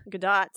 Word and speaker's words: Gadot: Her last name doesn't rhyme with the word dough. The T on her last name isn't silent. It's pronounced Gadot. – Gadot.